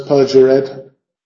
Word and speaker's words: perjured: Es perjured, no es perjured, dos sílabas, y murderous, murderous, murderous, dos sílabas.